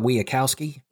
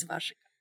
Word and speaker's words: Wasikowska: Is it not Wiakowski? Wiakowski? – Wasikowska. I love to hear people butcher these names, but yes, according to the internet, it's Wasikowska.